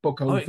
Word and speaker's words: Bokhove: Yeah, I think the end, this should not be Hove, but goes up, Bokhove.